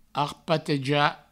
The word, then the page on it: arpatejar: arpateja